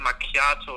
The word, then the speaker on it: Macchiato: Also kannst du ja im Italienischen H macht H. Wenn dann nach dem C ein H kommt, dann wird das K ausgesprochen. Also Bruschetta und Latte Macchiato und nicht Latte Macchiato oder so eine Scheiße.